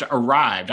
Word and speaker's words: Arryved: I believe it's pronounced Arryved. I should have checked with you on that one, David, but it's Arryved spelled with a Y instead of an I, but out of Boulder, Colorado.